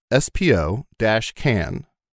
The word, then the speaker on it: Spokane: Actually, Thomas goes on to, and I didn't even see this, if you say Spokane, it's pronounced S-P-O-dash-can. – Spokane. – Spokane. And not Spokane. Okay. Which is a common mistake people make. Spokane.